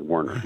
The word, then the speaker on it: Werner: It's Warner, not mm-hmm. Warner, correct? – Yes, okay. Like the ladders and the trucks, but we're a different family. Werner, yeah.